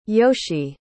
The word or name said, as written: yoshe